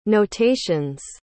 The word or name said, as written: notations